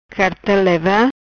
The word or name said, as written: Carta leva